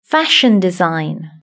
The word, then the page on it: fashion design: British English